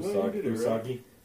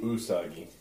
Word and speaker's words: Usagi: Usagi. – Start with a U. – Why? – Yeah, it starts with a U. Usagi. – Yeah, it's Usagi Yojimbo.